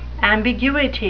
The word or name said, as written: ambiguity